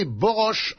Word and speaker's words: Borås: Borås, prononcé Borosch en suédois, une petite ville de 70 000 habitants à 60 km de Göteborg.